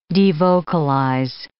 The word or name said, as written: devocalize